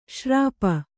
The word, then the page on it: ಶೂರ್ಪ: śurpa